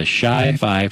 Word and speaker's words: Chi Phi: Scott Kretschmar and six other students were members of the university's XI chapter of the Chi Phi fraternity.